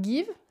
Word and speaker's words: Geev: Il y a une application qui s'appelle Give, ça s'écrit Geev.